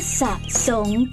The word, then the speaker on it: สระ: อ่านว่าสะสง